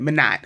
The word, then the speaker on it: Minot: Do I pronounce the T? My God, this is so embarrassing. I promise I can read y'all. Minot, North Dakota. That's the pronunciation I'm sticking with, Minot.